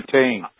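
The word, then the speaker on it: Pétain: Now, it says the government, I'm not sure how you pronounce it, the government of Marshall, P-E-T-A-I-N. – Petain. – I usually call it Petain.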